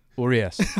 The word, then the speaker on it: Urias: Say Urias. Let's see. Say Urias. – Urias. – Oh, there you go. – Not bad. – I heard you say Urias.